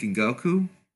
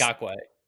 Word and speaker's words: Ngakoue: And they may have replaced, because they lost Yannick Ngakoue, or that's not how you pronounce his name. – Not yet. That's Ngakoue.